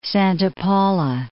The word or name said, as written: Santa Paula